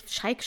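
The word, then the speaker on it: Shayk: Ich habe auch gelesen, dass Irina Scheik, Scheik spricht man sie so aus eigentlich,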